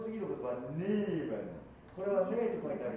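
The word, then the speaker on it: Neben: A の音が、えい、えいというふうに、やや E に近く、こっち少し上で発音される感じ。ネーベン。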